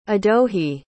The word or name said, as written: adohi